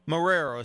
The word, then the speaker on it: Marrero: If you're down in New Orleans way, come on, over in, now I'm going to pronounce this correctly, Marrero, is that right? – That is correct.